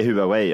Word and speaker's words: Huawei: Är det Huawei? – Jag säger Huawei, ja.